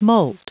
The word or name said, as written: molt